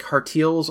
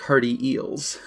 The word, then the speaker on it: Hartials: So like Hartials or like Hearty Eels? – The first one.